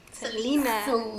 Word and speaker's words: Selena: Selena.